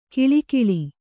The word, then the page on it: ಕಿಳಿಕಿಳಿ: kiḷikiḷi; dial.